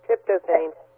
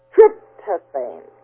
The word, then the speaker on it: tryptophan: Tryptophan. – What? – Tryptophan. – Tryptophan.